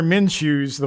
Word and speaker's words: Minshew: Am I saying his name right? Is it Minshew? Minshew? – Minshew.